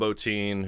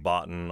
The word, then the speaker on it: Bottin: And then Rob Bottin, who I guess I've pronounced Botten on numerous occasions, but apparently I'm very, very wrong.